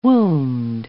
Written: wombed

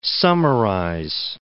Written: summarize

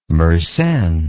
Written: Mersenne

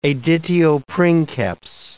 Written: editio princeps

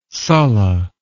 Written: sulla

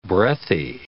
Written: breathy

